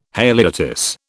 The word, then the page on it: haliotis: British English